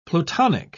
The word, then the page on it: plutonic: British English